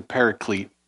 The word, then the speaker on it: paraclete: Sometimes you hear it, you hear it pronounced like this, the paraclete.